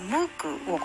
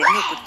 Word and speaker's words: MOOC: ムークをご存知ですか MOOC と書いてムークと読む